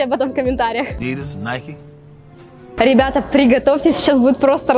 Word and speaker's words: Nike: Если в голове прозвучало Nike, то ставь лайк. Но на самом деле название этого бренда произносится совершенно не так. Название происходит от имени греческой богини победы Ники и произносится Nike.